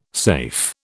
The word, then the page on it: safe: British English